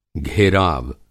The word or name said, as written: घेराव